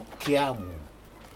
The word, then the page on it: keiamu: polynesian